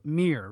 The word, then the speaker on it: Mir: So Mir, right? – Yep. – And he said Mir was like, they had dressing rooms right next to each other or something. And as he was leaving, Mir says to Brock Lesnar something like, yeah, this is real wrestling.